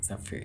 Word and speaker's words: Sapphire: Como é que fala? Sapphire? Sapphire. É isso aí.